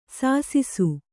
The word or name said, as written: ಸಾಸಿಸು